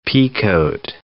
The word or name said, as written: peacoat